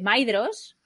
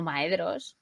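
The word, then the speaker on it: Maedhros: Ah, bueno, tú me enseñaste a decir Ainulindale, en lugar de Ainulindale, Ainulindale, y gracias a ti, ahora sé que se dice Maedros y no Maedros. – Ahí está. – Pero bueno, aún así se nos escapa. Yo creo que cuando llevas toda tu vida diciendo Maedros, decir de repente Maedros es muy difícil si no lo piensas.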